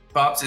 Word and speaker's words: P0pps: Is Pops right pronunciation? I don't know if you got zeros just to throw me off a little bit. – Pops is right.